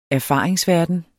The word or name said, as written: erfaringsverden